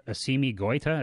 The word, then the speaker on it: Assimi Goïta: What was the catalyst for those coups, both of which were supposedly led by, I think it's Assimi Goïta? Is that how you pronounce the coup leader's name? – Yeah, that's how I would pronounce it.